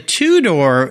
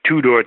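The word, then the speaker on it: TuDor: Well, the two-door is spelled T-U capital D-O-R, if I've got my spelling right, correct? – And that's a Ford pronunciation and spelling. Ford did that in their advertising. They spelled it two-door, T-U capital D-O-R, Yeah, super deluxe sedan.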